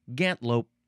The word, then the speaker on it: gantlope: Really kind of looks like gantelope because there's an E in the middle, but you can also spell it without the E, probably because it's pronounced gantlope.